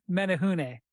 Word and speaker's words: Menehune: Menehune.